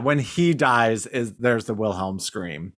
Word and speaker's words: Wilhelm: And then there's a character, Otis Wilhelm, which is funny because is that how you say it? The Wilhelm scream? – Yeah.